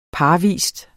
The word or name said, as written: parvis